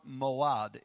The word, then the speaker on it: Moed: M-O-A-D-I-M. Or Moad, M-O-E-D, Moed, Moad, however you want to pronounce that. It's M-O-E-D, but it comes from this longer word, M-O-A-D-I-M, okay, is where they get the word feasts from.